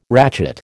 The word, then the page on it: ratchet: American English